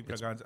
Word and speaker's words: Bragança: Maybe Braganza. I don't know. – It's Braganza in Anglo. – Yeah. – It's pronounced as a Z. – Yeah, because it's got the accent mark, but on the bottom.